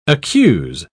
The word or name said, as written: accuse